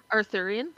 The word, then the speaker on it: Arthurian: I don't know if I'm saying it right. Am I saying it right? – Arthurian. – Arthurian.